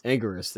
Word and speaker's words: Anguirus: a Garrus? No, not a Garrus. I said that wrong. I just saw him last week as well. Anguirus, there you go.